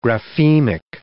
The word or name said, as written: graphemic